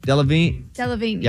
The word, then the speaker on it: Delevingne: I don't know how to say this girl's name. Cara Delevingne? What is it? – Delevingne. Yeah.